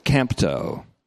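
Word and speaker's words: kempto (κάμπτω): Accents on the first syllable there, kempto.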